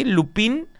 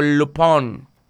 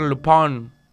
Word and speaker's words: Lupin: Se escribe Lupin, así, Lupin, pero es algo así como Lupón. A ver, Lupón.